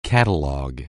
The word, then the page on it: catalog: anglais